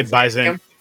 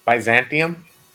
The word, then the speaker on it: Byzantium: I say Byzantium. – Say it again? – Byzantium.